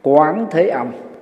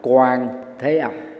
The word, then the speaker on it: Quán Thế Âm: Vào Đề Tào Quỷ, dịch giả Phan Tăng Khải trong Vô Lượng Thọ Kinh dịch là Quán Thế Âm, hoặc có thể đọc là Quang Thế Âm, vì cái chữ Hán đó, quang với quán đó, có hai cách đọc, đọc cách nào cũng được xem là đúng.